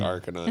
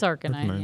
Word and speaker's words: Arcanine: Or I don't know how everyone else pronounced it. I always pronounce it Arcanine. – It's Arcanine, yeah.